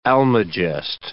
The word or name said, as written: almagest